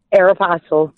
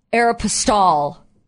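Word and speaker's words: Aéropostale: Aeropostale. – Thank you! Yeah, she was not at Aeropostale.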